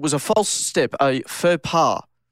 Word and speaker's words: faux pas: Okay, I'll get the etymology for you as well, but it is spelled F-A-U-X space P-A-S. So it would be a faux pas to call it a faux pass or a faux pass. – Fox pass.